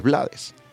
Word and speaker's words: Blades: Y él respondió, si mal no recuerdo, palabras más, palabras menos, yo soy de Panamá, hablamos español y nosotros debemos pronunciar lo que leemos. Ahí dice Blades, por lo tanto yo entiendo que es Blades.